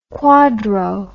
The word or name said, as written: quadro